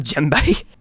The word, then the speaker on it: djembe: Now, first question, what the hell's a djembe? And am I saying it right? – Yeah, you're saying it right. It's a djembe. It's a silent D.